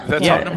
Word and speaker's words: Tottenham: The Tottenham Hotspurs. – Tottenham Hotspurs.